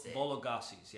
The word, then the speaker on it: Vologases: Vologases. – Yeah, Vologases, yeah. I think it's just it's the way I've always pronounced it.